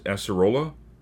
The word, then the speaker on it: acerola: As- As- acerola.